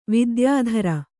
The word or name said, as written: ವಿದ್ಯಾಧರಿ